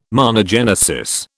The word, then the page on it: monogenesis: British English